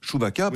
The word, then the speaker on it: Chewbacca: Les sabres laser, les vêtements Dark Vador, les masques de Chewbacca, les gobelets... – Chewbacca, pardon, j'en rallonge d'une syllabe.